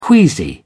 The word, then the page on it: queasy: British English